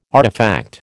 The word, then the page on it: artefact: American English